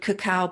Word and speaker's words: cacao: And if I could be in love with a food, it would be cacao. Now that's, people say, is that the same as cocoa? But it's the raw unadulterated bean that comes from South America or Mexico. It's C-A-C-A-O, pronounced cacao.